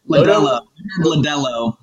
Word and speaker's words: Lodolo: So, Nick Lodolo? – Lodolo. Like rhymes with the beer, Modelo.